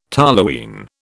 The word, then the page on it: toluene: British English